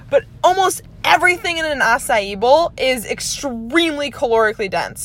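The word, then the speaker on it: açaí: Things like acai bowls. I think I'm saying that right. It's spelled like aca bowl, but I think it's pronounced acai, you know, because it's so bougie, the acai bowl.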